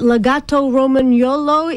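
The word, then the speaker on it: Lagotto Romagnolo: I read that Legato Romagnolo is one of them, the Italian water dog.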